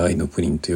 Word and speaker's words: AIのプリン: AI のプリンと書いて愛のプリンと読むらしいんですが、